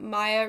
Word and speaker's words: Maya: I'm also on Tumblr at Maya Reviews.